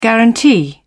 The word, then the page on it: guarantee: British English